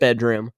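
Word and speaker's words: bdrm: It's pronounced bedroom, but it's spelled without any of the vowels, because they're too cool for that kind of thing.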